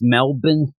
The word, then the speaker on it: Melbourne: I was excited to talk to you and say again, you know, it's pronounced Melbourne. Eric in Australia. – Melbourne? – I always like that. It's Melbourne is the actual pronunciation.